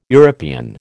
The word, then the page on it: European: American English